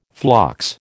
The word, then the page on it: phlox: American English